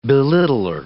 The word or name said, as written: belittler